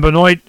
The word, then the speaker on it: Benoit: B-E-N-O-I-T, how you say it, Benoit,